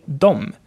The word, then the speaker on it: de: The same thing goes for de, which means they. In the spoken language, you would just say dom in this case. Like we taught you, you can pronounce the words like they are written, but especially in this case, dom, the language really flows better than when you would say de.